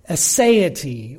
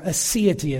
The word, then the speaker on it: aseity: The theological term for this truth is aseity, or aseity as some pronounce it, A-S-E-I-T-Y.